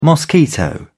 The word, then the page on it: mosquito: British English